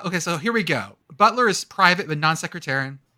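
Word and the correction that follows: nonsectarian: Non-sectarian? – Oh, I said that wrong, didn't I? – Non-secretarian.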